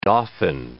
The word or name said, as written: dauphin